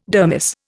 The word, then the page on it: dermis: Indian